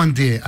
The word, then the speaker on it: Antier: ¿Antier o Antié? Antier, con esa, para escribirlo, es con esa R que se escribe y no se pronuncia.